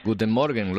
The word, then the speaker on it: Guten Morgen: Guten Morgen, ¿lo he dicho bien? – Sí, perfectamente, buen acento.